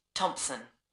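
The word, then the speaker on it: Thomson: Janet Thompson. – Is that Thompson spelled with a P? – No, it's T-H-O-M-S-O-N.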